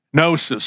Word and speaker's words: gnosis: Gnosis. So G-N-O-S-I-S, the G is silent.